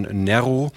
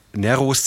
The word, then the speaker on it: Nero: Zu Neros Zeiten hieß der Mann Nero, also kurzes E. Inzwischen ist es üblich, den betonten Vokal in einem Wort im Deutschen zu längen, also wird Nero draus, so wie Forum, das eigentlich Forum heißt.